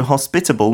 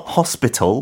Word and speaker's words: hospitable: So hospitable, not hospital. Uh-huh. 병원 그 단어랑 조금 비슷한 uh, pronunciation 있지만 hospitable uh-huh.